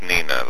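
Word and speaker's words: Nenagh: Nina, that's how you pronounce it, correct? – Yes, it's pronounced Nina, like the girl's name.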